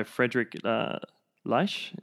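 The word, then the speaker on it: Friedrich Leisch: And so this idea was picked up by Frederick Leisch is how I believe I say his name. – That's absolutely correct. As a German, wonderful.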